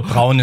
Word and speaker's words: Brown: Nee, also, Brown ist schon richtig.